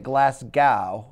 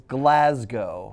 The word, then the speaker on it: Glasgow: When talking about Josh Taylor's upcoming fight with Jack Catterall, you mispronounced Glasgow. – I thought it was I could have sworn it was Glasgow. – Normally, I don't give a shit, but I like you guys, so I thought I would help you out. You pronounced it Glasgow. – Yeah. – The correct way to pronounce it is Glasgow.